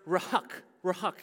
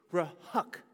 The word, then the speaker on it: ruach: You know, as a matter of fact, in Hebrew, it's pronounced ruach here. And it's one of those words that sounds exactly like what it means. It sounds like a breath that you're taking, ruach.